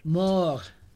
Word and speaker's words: mort: Muerte, not muerte. That's Spanish. – Mort. – Yeah. – M-O-R-T.